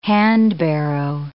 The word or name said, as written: handbarrow